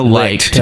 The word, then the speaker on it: Licht: It's Licht or Licht, L-I-C-H-T. – Oh, yeah. Daniel Licht.